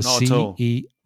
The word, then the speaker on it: CEO: We actually specifically mention or pronounce letter individually. – Each letter. That's right. – So we don't make it into a word. CEO. – Yeah. – You say CEO, which is more difficult because it's slower. You have to pronounce each letter.